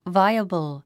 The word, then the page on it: viable: váiəbl; バイアブル